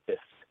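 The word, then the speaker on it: IFTTT: So one of the things, and like I said, I feel really ridiculous not having checked this out previously, is the IFT, pronounced like gift.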